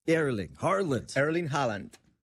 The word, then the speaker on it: Erling Haaland: Erling Haaland. – Erling Haaland.